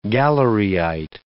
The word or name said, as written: galleryite